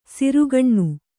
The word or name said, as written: ಸೆರೆಗಣ್ಣು